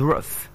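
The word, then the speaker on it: Ruth: All right, the next one is the word R-O-U. T-O. Let me help with the name R-U-T-H. R-U-T-H is not root, it's pronounced roof. Again, roof.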